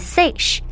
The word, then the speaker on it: seiche: 湖震这个词的发音是 Seiche，源于瑞士法国的口音，它的意思是打滚。